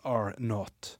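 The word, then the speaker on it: R0: R-0, men det uttales R not.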